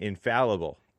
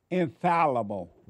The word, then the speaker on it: infallible: The Bible is inflallable. – And I try to correct you saying infallible. – Infallible. – Like Alabama. – Did I say it right? Infallible. – That's correct. Twice. You've said it right twice.